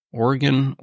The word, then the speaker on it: Oregon: Do you know that 72 years ago in the great state that I'll never be able to pronounce, Oregon? – There you go.